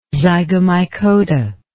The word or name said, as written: zygomycota